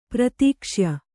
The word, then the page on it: ಪ್ರತೀಕ್ಷ್ಯ: pratīkṣya